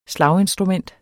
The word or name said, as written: slaginstrument